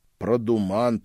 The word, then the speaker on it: продуман: Продуман тут назывался подобный тип характера, с ударением на «а».